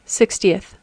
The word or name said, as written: sixtieth